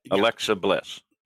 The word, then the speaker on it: Alexa Bliss: What are they going to do with Alexis Bliss now? Alexa Bliss. Do I keep saying it wrong? – You said it right a couple times in there. – Yeah. Alexa Bliss.